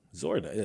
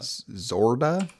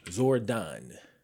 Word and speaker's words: Zordon: Zordon. Like, just sound it out. – Zorda? – Zordon.